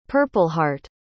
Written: purpleheart

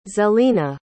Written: zelina

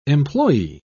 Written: employe

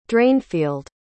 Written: drainfield

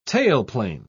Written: tailplane

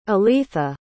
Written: aleatha

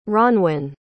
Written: ronwen

